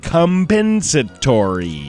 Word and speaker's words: compensatory: so they awarded him $10 million in compensatory. – Now use it in a sentence. – I was going to say, be careful with that first pronunciation you just said. Compensatory.